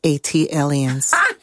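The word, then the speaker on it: ATLiens: That is pronounced Atlians. – Thank you. – Like Atlanta alien, AT alien.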